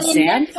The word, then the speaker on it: Xanth: And there, it's called Xanth. How do you spell that? – What? – That this with an X. – X A N T H. Xanth.